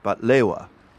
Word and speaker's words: baklava: One is baklava, or as the Egyptian version of it is often pronounced, batlewa.